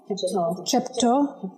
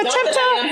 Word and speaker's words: Chepto: We meet Chepo. – Chepto. – You think all the Kenyans are like, Chepto, get that T. We pronounce every consonant in this language. Yeah, I just dropped the T.